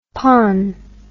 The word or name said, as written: pon